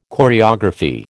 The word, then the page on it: choreography: American English